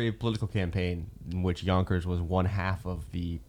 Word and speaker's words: Yonkers: Yonkers rhymes with bonkers.